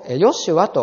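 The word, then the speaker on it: ヨ シ ュア (ישוע): この方がご自分の民をその罪からお救いになるのです。イエスとつけなさいとありますが、イエスという名は、まあ、ギリシャ語の読み方なんですね。ギリシャ語の名前であります。旧約聖書のヘブライ語では同じ名前をヨシュアと発音します。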